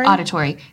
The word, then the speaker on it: auditory: is the oratory? Is that how you say it? – Auditory.